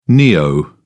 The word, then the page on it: neo: British English